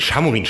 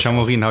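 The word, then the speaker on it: Šamorín: Also jetzt am besten erwerben und am Wochenende signieren lassen in Samorin. – Ja, genau. – Nee, Chamorin spricht man es, glaube ich. – Chamorin habe ich auch gelernt, als ich letztes Jahr da war zur Berichterstattung.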